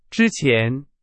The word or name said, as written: 之前